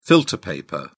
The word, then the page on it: filter paper: British English